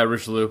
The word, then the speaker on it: Richelieu: Yeah, Richelieu, Cardinal Richelieu.